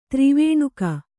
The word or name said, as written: ತ್ರಿವೇಣುಕ